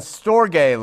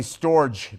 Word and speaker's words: storge: So on the board, agapeo, agape, phileo, Philadelphia, telo, love, eros love, and storge love, okay? And it's really storge, but it's pronounced storge, and so it's kind of a weird-looking word on the board.